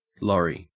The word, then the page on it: lorry: Australian English